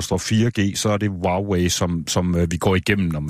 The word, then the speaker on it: Huawei: Jeg har lært at udtale det rigtigt, jeg har øvet mig i det, det hedder Huawei.